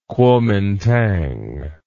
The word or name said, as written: kuomintang